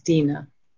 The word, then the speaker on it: Dina: Yes, I was going to say, my name's actually, it looks like Deanna, but it's actually pronounced Dina.